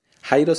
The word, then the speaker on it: Jairo: Mi nombre es Jairo Bejarano Carrillo. – 2. ¿Cómo se escribe tu nombre? – Jairo se escribe J-A-I-R-O.